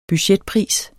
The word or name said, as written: budgetpris